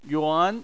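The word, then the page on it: yuan: tone 2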